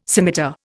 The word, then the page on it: scimitar: Indian